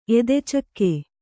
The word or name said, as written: ಎದೆಚಕ್ಕೆ